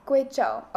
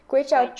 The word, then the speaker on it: Guizhou: I'm not exactly sure of the pronunciation. guizhou. Gui, uh, Guizhou. Guizhou. Okay, Guizhou. Guizhou, China. Yeah.